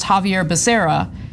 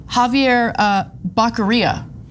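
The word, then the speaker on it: Xavier Becerra: Javier Becerra, his name is pronounced Javier Becerra. He recently called him Javier Baqueria.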